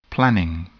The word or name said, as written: planning